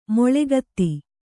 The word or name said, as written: ಮೇಳಗೀತ